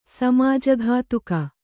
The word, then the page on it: ಸಮಾಜಘಾತುಕ: Kannada